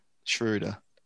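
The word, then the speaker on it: Schröder: But you said it wrong for starters. – Schroeder? – Yeah. – Schroeder. – There you go. He's German, man. Got to get that pronounced.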